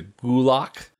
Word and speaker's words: gulag: technically we're saying it wrong, and everybody in this movie is saying it wrong because it's pronounced closer to gulak with more of a GK combination sound.